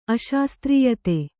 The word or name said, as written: ಅಶಾಸ್ತ್ರೀಯತೆ